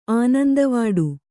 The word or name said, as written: ಆನಂದವಡೆ